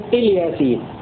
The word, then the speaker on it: إلياسين: بكسر الهمزة بدون مد، وسكون اللام بدلا من كسرها في كلمة الياتين، على أنها كلمة واحدة موصولة لفظا لا خطا، لأنها مقطوعة رسما كلمة إل وحدها وياسين لوحدها، ولكن في نطقها تكون الياسين.